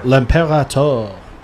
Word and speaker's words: L'Imperator: I'm sorry, I missed the L. How do you say that? Go on. – Which horse? – The four. – The four. – L'Imperator. – Yeah, there we go.